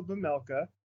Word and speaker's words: Vejmelka: Karel Vejmelka? – Close, very close. Well done. Not bad for a first attempt. Okay. Yeah, it's Karel Vejmelka. So the, the J is silent.